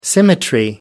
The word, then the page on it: symmetry: American English